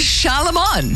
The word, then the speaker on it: Charlemagne: It's Charlemagne.